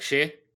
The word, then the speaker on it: sidhe: S-I-D-H-E, which I think is actually pronounced like she.